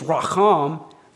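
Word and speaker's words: racham (רָחַם): The Hebrew noun rechem means womb or matrix, that reproductive organ that's unique to females. The verb form of this noun is racham. The difference in spelling and pronunciation is due to vowel differences only. It's the same Hebrew consonants. We're just trading, if you remember from Hebrew class, you're just trading the A-class vowels or E-class vowels for A-class vowels.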